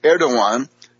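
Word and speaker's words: Erdoğan: So the hysteria of the Erdogan, written Erdogan but pronounced Erdogan, and Davutoglu clique.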